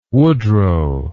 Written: woodrow

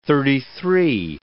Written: thirty-three

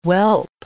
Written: whelp

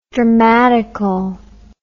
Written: dramatical

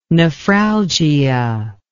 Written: nephralgia

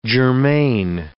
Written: germane

